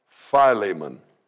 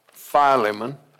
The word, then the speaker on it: Philemon: Now, I call it Philemon. Some call it Philemon. Put the long E in there, don't they, Sherry? And so Sherry says, Pastor, you say that wrong. And it is Philemon, but because she corrected me, it's Philemon. But it definitely is not Philemon.